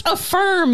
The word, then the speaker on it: Affirm: What is A-Firm? – It's equivalent to, what's that one service that lets you buy? – Oh, shit, dude. – It's pronounced Affirm, you weirdo.